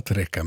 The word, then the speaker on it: Treccha: Trecca stavas T-R-E-C-C-H-A. Ordet är 1600-tals italienska och uttalas La Trecca, men det kan vara en gammal stavning av La Treccia, flätan.